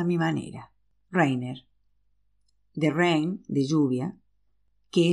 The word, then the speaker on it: Rainer: Ya le he dicho que mi nombre no se pronuncia Rainer, sino Rainer.